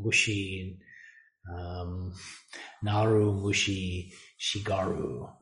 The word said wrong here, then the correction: shigure: No, shigure which I butchered, it translates as within the chorus of inste- insect sounds, I am also an insect.